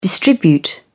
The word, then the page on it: distribute: British English